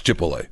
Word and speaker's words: Chipotle: Did you just use the Al Sharpton pronunciation of Chipotle? – Chipotle? – Yeah, you did. Accepted that that's the way it's pronounced. – I don't call it anything else. – I don't. – I can't see it on the sign. I can't read. It's Chipotle. – It's Chipotle. Because everybody said that.